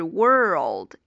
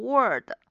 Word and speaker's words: world: 读起来就是 world，一定要到位，千万不要读成 word，或者还有的同学会读成 world。首先注意是先发这个 r 音 word，然后之后呢再去拼这个舌侧音 world。